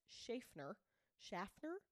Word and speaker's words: Schaffner: and Dan Cooper hands the stewardess, her name is Florence Schaffner, spelled like Schaefer but with an N-E-R, like Schaffner. – Is it S-C-H? – S-C-H-A-F-F-N-E-R, Schaffner, right?